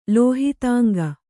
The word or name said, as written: ಲೋಹಿತಾಂಗ